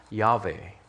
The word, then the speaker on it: Yahweh: And so that is YHWH, or as we pronounce it, Yahweh.